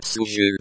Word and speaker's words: Служил: Served, past tense, masculine, singular, pronounced like служил. Note the L sound at the end, it is a soft L.